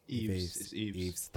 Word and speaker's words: Yves: How do you pronounce that? Yves? Is it? – Eves. – Thank you.